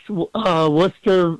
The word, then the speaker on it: Worcester: I like his message, and, you know, you pronounce Worcester very well.